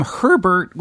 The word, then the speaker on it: Herbert: But then there's the name Herbert, which adds a layer of confusion to the whole thing because you pronounce the H in that sense.